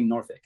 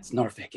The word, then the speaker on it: Norfolk: But like if the most obvious, like the biggest one is how people say Norfolk. If you don't, if you pronounce the L, you're a tourist.